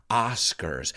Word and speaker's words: Oscars: Cuidado con esa pronunciación. No es Oscar en inglés, sino Oscars.